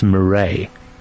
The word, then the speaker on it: Mireille: One of our listeners saying her name is pronounced Mireille. – Okay, well, I have it from the French. It's Mireille, but you know, however she pronounced her name, and thank you, you know, for the correction.